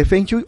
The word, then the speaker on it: Feng Shui: Tips de Feng Shui. Este, que la pronunciación debería ser Feng Shui, ¿no? – Sí, es, es feng, shui, Muy bien.